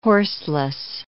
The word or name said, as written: horseless